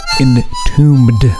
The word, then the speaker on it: Entombed: Here's a great song from a great band who are called Entombed. Got a silent B right there.